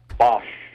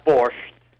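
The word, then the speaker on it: barszcz: Shannon, I was wondering if you ever heard of fermented rye Bosch. Not borscht, Bosch. That's the way Polish people pronounce this type of soup.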